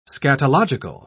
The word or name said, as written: scatological